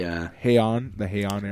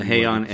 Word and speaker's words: Heian: The Heian era. – The Heian era, and that would be.